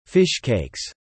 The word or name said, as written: Fish Cakes